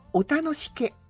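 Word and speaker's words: 大楽毛: そうで、ちなみにだけど、まあ 15 個全部クイズ出すのはちょっと無理があるので、 1、2 個ね、もしよければ大きく、楽しい、髪の毛の毛、これを何と読むでしょうか。 – 全然わかんない。 – だよね。 – これお楽しけ。 – どこそれ。 – これ釧路の地名なんだって。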